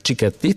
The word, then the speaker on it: Cicchetti: I Pietro Cicchetti, mm-hmm. jeśli dobrze wymawiam tak. jego nazwisko. Cicchetti, tak? – Tak, tak, tak.